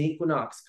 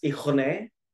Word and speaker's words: Equinox: I recently learned that it's not pronounced ichone. I thought that that's just how fit people spoke, just very elegant and like French. So I learned it was Equinox, and I ended up becoming a member because we get a really sick discount.